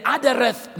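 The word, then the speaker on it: Adderet: The one that's been referred to here is the Adareth mantle. If you want to spell it, it's A-D-D-E-R-E-T, Adareth. Adareth mantle.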